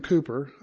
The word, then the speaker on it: Cowper: And There is a Fountain was written by William Cooper. I know it's spelled Cowper, but you pronounce it Cooper.